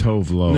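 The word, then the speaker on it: Tove Lo: It's pronounced Tove Love. – No. – Love as in of bread?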